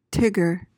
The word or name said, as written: tigger